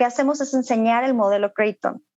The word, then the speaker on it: Creighton: En mis redes es Create on Model MX, Creighton, de, la Universidad de Creighton, que es donde, donde se inició todos los estudios para generar este, este sistema. Se escribe C-R-E-I-G-H-T-O-N, Creighton.